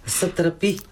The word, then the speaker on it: Сатрапи: Сатрапи.